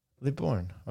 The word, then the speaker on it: Libourne: Libourne.